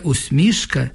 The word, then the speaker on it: усмішка: І злітає пісня, молода, крилата, як твоя усмішка, як зоря в гаю. Володимир Сосюра. Основний наголос у цьому слові – усмішка. Усмішка, як варіант, може бути лише в поезії.